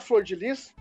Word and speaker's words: Flordelis: E é impossível a gente não falar do caso da deputada Flor de Lis, que muita gente jurava que se pronunciava Flor Delis, inclusive eu.